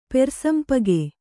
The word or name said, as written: ಪೆರ್ಸಂಪಗೆ